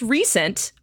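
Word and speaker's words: Rhysand: His name's Ryzen. He's great. It's supposed to be pronounced recent, but it is spelled R-H-Y-S-A-N-D-S.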